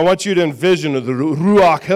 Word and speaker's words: Ruach: Ruach. – Say it like this. Ruach. – Ruach. – Sounds like you're getting ready to spit. Ruach.